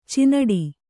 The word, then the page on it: ಚಿಣ್ಣಾಟ: ciṇṇāṭa